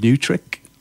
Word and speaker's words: Neutrik: But it's called New Trick. But it's spelled NEUTRIK.